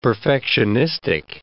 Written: perfectionistic

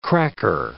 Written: cracker

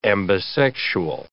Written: ambisexual

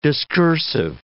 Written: discursive

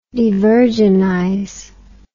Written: devirginize

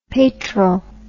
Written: peytral

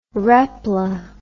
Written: repla

